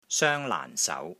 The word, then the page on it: SOENG1 LAAN4 SAU2: Cantonese